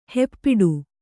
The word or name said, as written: ಹೆಪ್ಪಿಡು